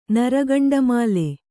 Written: ನರಗಂಡಮಾಲೆ

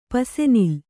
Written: ಪಸೆನಿಲ್

